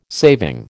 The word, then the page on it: saving: American English